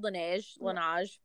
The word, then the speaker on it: Laneige: The Laneige lip sleeping mask. – You didn't do bad. Laneige. – Okay. – However you want to say it.